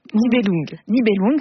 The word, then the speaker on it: Nibelung: Nibelung, oui, on m'avait mis un petit piège.